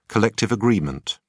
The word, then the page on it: collective agreement: British English